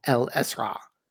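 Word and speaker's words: Isra': In Arabic, this is called El Esra. It actually ends in an ayn. The last two letters are A and ayn, which is just impossible to pronounce if you're not a native Arabic speaker.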